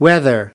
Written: whether

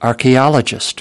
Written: archaeologist

